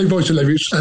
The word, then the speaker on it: Wojtulewicz: You say Wojtulewicz, and unless you're Polish, I don't expect you to get it.